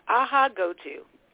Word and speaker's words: Ahaghotu: It's close. It's Ahaghotu.